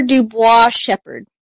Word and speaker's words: Duboise-Shepherd: It's Amber Duboise-Shepherd. It's a French last name.